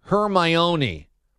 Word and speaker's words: Hermione: V strikes back tweets, D-A, it's pronounced Hermione, but don't get stuck on the O.